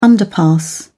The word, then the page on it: underpass: British English